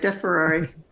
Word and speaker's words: DeFerrari: DeFerrari, like the sports car.